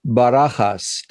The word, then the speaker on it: Bajas: And to start off with, my guest is Coral Bajas.